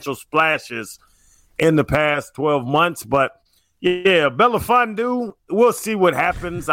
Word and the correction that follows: Melifonwu: I don't know if I'm saying his name right. – You're not. – Like, we'll see if he can replace – I'm not. Belafondo, what's his name? – No, Melifonwu.